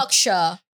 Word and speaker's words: Berkshire: Berkshire.